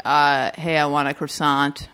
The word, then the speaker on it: croissant: And then, you know, the one with the yellow hair, she says, that Beth Barrett's character goes, oh, it's croissant, the T is silent.